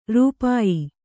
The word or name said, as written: ರೂಪಾಯಿ